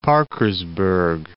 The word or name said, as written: Parkersburg